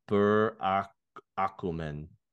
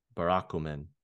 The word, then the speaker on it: Burakumin: This person is called I'm I'm probably gonna butcher this. Uh Burakumin. Burakumin. B U R A K U M I N.